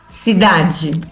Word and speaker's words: cidade: Eu, además de pronunciar G, eu pus la E, eu pronunciei la E como se fosse uma I. – Uma I, claro. – Cidade.